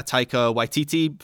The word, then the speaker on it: Taika Waititi: There's a film out at the moment, a Taika Waititi film, first time I've ever pronounced that correctly, which is based on the documentary about, let's just say I did, Ryan.